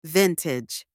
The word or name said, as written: vintage